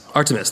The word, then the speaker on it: Artemis: He made silver shrines of Artemius. I pronounce Artemius? Artemis, thank you, Artemis, brought in a lot of business.